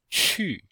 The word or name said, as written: qu4